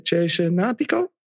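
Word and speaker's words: Cesenatico: Chesenatico? – Ongeveer, ja. Maar je moet het wel in één keer zeggen, hè? Niet... In drie verschillende... – Chesenatico, Chesenatico.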